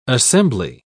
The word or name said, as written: assembly